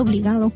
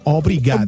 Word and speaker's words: obrigado: Porque van a pensar que estás diciendo obligado. – No, obrigado.